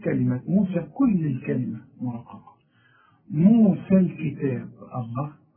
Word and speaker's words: موسى: طب ما هي موسى فيها إيه؟ والليل بتتحول إلى ألف في النطق. موسى. أنا لقيت إيه لما وصلت قلت موسى الكتاب. الحكم ده هتاخده فيما بعد. لكن إذا وصلت إلغي الياء مؤقتا. موسى الكتاب وكأن الياء غير موجودة.